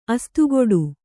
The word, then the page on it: ಅಸ್ತುಗುಟ್ಟು: kannada